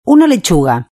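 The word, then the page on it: una lechuga: Spanish (Spain)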